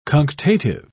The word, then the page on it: cunctative: British English